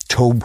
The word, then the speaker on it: Tobe: I'm talking about the original one, 1974, by Tobe Hooper, I think he pronounces it. Maybe Toby, but it's Tobe Hooper.